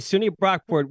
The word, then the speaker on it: SUNY Brockport: Now, look, you went to SUNY Brockport. Did I say that right first? – You did. SUNY Brockport.